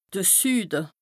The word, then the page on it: de Süde: Bas Rhin; Strasbourg